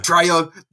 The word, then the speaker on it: Trejo: Is it Trejo? Oh, I don't know if I'm pronouncing that correct. – Treo, that's the one.